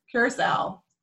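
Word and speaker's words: Curaçao: I'm really glad that we've had this podcast so I can stop calling it Caraco. – It's the same as the drink, Curacao. That's how you can remember it. The blue drink.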